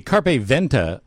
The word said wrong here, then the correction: Carpe Ventus: organization, am I saying that right? – Ventus. – Carpe Ventus. – Ventus, sorry.